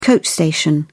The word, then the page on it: coach station: British English